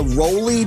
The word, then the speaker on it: ROLI: Like, or if you want to get back into it, it's called the Roly piano system. That's spelled R-O-L-I.